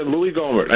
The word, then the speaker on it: Gohmert: Is it Louis Gohmert? – Yeah, Louis Gohmert.